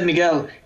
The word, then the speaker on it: Miguel: Like, I was surprised when he said Miguel, he pronounced it with a real Spanish accent, which was like not how I had been pronouncing it.